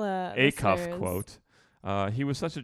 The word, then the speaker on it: Acuff: Fellow Grand Ole Opry star Roy Acuff commented, Acuff? Acuff. I said that twice now, eh? – Yeah. – Acuff? – Acuff. – Fucking me and my pronunciation. – The wrong emphasis on the wrong syllable.